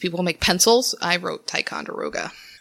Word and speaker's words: Ticonderoga: So you're saying Ticonderoga? – Yes, sir. – You said it right the first time. – Ticonderoga? – Yeah. – Oh, that's exactly how you say that.